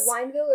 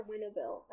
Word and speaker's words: Wineville: Was it Wineville or Winneville? I couldn't remember. – I heard it pronounced Wineville in the movie, so I'm gonna say Wineville.